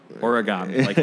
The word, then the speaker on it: AURAGAMI: So, yeah, Natalie was like, it's Aura. AURAGAMI, like someone's aura. And I'm yeah. like, got it. I'm gonna see if I can not fuck this up. So you have AURAGAMI that came out this summer, yes.